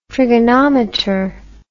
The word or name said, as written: trigonometer